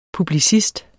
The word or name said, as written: publicist